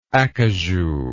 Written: acajou

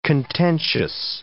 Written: contentious

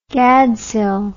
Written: gadshill

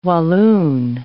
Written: Walloon

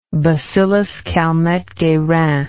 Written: bacillus Calmette-Guerin

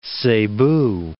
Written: Cebu

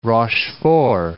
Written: Rochefort

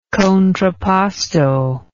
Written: contrapposto

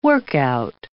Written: workout

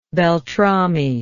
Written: beltrami

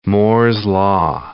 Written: Moore's law

